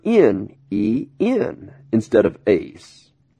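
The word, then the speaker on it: ace (εἰς): Now, the English word in, I-N, in the Greek is the preposition eis, E-I-S, and we pronounce it ace, A-C-E, okay?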